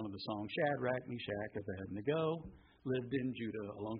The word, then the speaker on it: Abednego: When I say Abednego, I look at my wife, she's got a smile on her face. She says, "That's not how you say it. It's Abednego."